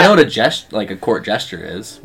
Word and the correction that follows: jester: Am I not saying it right? – Jester. – Jester? Yeah. Okay.